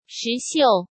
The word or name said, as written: Shi Xiu